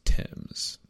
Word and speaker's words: Thames: The less fabulous, in the original and current sense of that word, truth is that it has always been pronounced Thames, and likely descends from the Gaelic word meaning river, which is pronounced similarly. The H was added to the word.